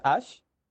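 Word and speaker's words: Æ: La AE e eh, se pronuncia Ash, así como el protagonista de Pokémon.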